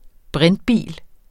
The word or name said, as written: brintbil